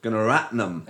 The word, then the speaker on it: Gunaratnam: And we have with us Katie Holmes and Carl Gunaratnam.